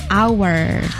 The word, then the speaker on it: hour: So when it's related to time, so actually this should be pronounced with hour. – Hour. – Hour. – The H is silent.